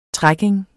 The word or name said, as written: trekking